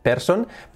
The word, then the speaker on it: person: Escrito person, person, pero su pronunciación, fijaos que es person, person. Tenemos la P bien fuerte, pa, pa, pa, la R, letra clave. Y luego son, fijaos esa terminación O-N, no es on, es in.